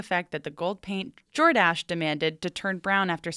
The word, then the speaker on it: Jordache: It's pronounced Jordache.